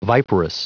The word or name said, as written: viperous